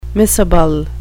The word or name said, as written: miscible